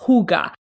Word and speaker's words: hygge: And they talking about a very specific aspect of culture, which is called, I believe it's pronounced hygge.